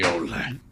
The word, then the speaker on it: Mignola: Anyway, Mignola.